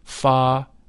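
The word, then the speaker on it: Pharaon: The whole mantram, Pha-Ra-On, is divided into three syllables, thus, Pha-Ra-On. The Pha is that of the musical scale. The Ra is a deep sound. It should be vocalized with a rolling R, Ra.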